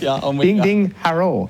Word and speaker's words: Ding Ding Harrell: That's how it's spelt, and that's how it's pronounced. Ding ding harrow.